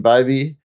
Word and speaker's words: Bybee: So today on the show, we've got Shane Bybee.